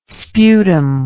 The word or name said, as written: sputum